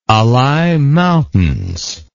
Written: Alai Mountains

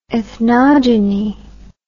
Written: ethnogenist

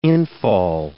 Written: infall

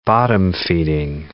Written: bottom-feeding

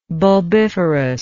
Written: bulbiferous

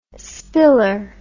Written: spiller

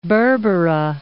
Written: Berbera

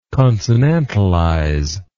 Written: consonantalization

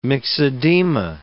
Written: myxedema

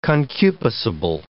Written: concupiscible

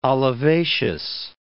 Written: olivaceous

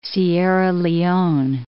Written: Sierra Leone

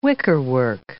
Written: wickerwork

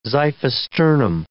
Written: xiphisternum

